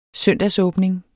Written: søndagsåbning

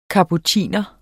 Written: kapuciner

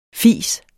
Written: fise

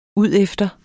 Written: udefter

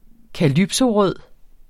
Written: calypsorød

